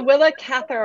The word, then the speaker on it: Willa Cather: It's the Willa Cather Award.